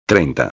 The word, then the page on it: 30: espanhol